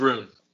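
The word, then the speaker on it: Rhûn: Is it Rune or Run? – It's Rune.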